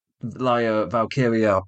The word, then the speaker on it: Valkyria: Roxanne Perez is now going to face Lyra Valkyria, pause to say it correctly.